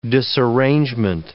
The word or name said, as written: disarrangement